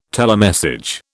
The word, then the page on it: telemessage: British English